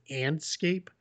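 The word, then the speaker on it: Andscape: But yeah, I highly encourage, Mark Spears wrote this for, I believe it's Antscape is how you say it.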